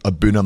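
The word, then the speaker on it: A'bunadh: So thinking about that at home, think of Abuna Matata. That's the kind of way to pronounce it.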